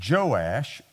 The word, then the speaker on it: Joash: We're going to be looking at this story when a king comes to him, and the king's name is Joash, or it's also, he's called Jehoash, and so it's either way.